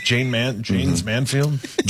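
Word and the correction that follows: Jayne Mansfield: Jane Mansfield.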